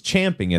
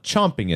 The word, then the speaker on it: champing: You're saying it wrong. It's champing at the bit, not chomping at the bit.